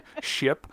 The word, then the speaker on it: ship: No, sorry, I mispronounced the word chip. – It's hard. Ship.